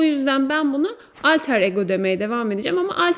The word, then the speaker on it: Alter ego: Öncelikle şöyle bir şey doğru okunuşu zaten yani İngilizce okunuşu aslında Latince bir kelime. Alter ego.